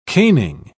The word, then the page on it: caning: British English